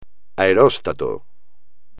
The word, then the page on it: aeróstato: espagnol